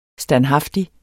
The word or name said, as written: standhaftig